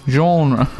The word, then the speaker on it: genre: How do you say genre? – Genre. – I thought that's you said it French.